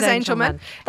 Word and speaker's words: Angelman: Every time I say it, I worry I'm saying it wrong, but it's Angelman. – It is Angelman.